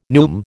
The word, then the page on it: neume: American English